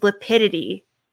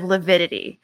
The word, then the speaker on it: lividity: Another thing John Pryor kept saying during one of his cross-examinations was lipidity instead of lividity, which he's been pronouncing things wrong.